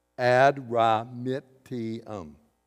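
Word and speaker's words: Adramyttium: We boarded a ship from that's how you pronounce it. It's five syllables. Ad-ra-mit-ti-um.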